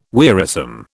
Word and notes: wearisome: British English

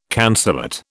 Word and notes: cancellate: British English

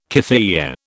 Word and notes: keffiyeh: British English

